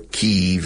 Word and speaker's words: Kyiv: We spent about six days in Moscow, five days in uh, Kiev or Kyiv as they pronounce it now, and uh, five days in Leningrad, which of course today is again called St. Petersburg, and then two days in Helsinki, Finland.